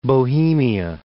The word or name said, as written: Bohemia